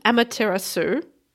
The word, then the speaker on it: Amaterasu: This one's called Amaterasu. I'm going to pronounce that as if it rhymes with tiramisu, because that sounds like a good approach.